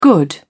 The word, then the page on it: good: British English